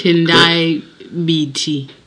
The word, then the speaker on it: Tendai Biti: Opposition Movement for Democratic Change Secretary for Finance Tendai Biti has said. Is that correct? – Tendai Biti. – Okay, getting help with the pronunciation there.